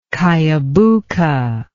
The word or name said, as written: kiabooca